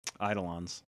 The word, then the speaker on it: Eidolon: And then in that case is EIDOLON, Eidolon.